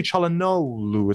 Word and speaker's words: Çalhanoğlu: It's actually Çalhanoğlu. It's like a W-L-U at the end.